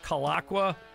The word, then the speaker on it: Kulaqua: K-U-L, aqua. – Okay, good. Thank you. – No, it really is. Aqua. K-U-L, aqua. – You can also find us on our Facebook page. Camp Kalakwa is spelled K-U-L-A-Q-U-A.